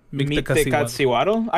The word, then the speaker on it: Mictlantecuhtli: Mictlantecuhtli. – Meet the Katsiwaddle.